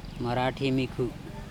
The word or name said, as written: maraThimikHu